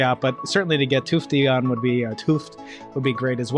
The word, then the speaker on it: Tufte: Tufte.